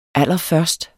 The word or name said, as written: allerførst